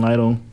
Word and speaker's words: Lytle: They can follow me on all the social media at Julian Lytle.